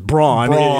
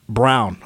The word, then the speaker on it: Braun: Because everybody thinks it's Braun. His last name's Christian Braun. And then I see in the pronunciation guide, when the SID comes over to tell me how you pronounce all these names, Brown. I was, oh, great. – Just change it. – Because it's spelled B-R-A-U-N.